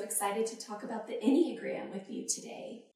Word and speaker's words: Enneagram: And you said it correctly, Enneagram.